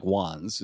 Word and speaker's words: Juanes: Juanes. Yeah. yeah. I don't know how to pronounce it. Uh, concert. It's, it's, it is pronounced, it sounds like Juan's, is the way it's, it's spelled, it right?